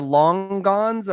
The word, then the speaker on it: longan: Help me with the pronunciation. The longans are longan. Longan. Yes.